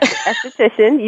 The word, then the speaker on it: esthetician: Esthetician, you were on the right path.